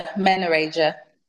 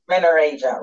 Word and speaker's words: Menorrhagia: What was the other one? The menorrhea? – Menorrhagia. – Menorrhagia, right.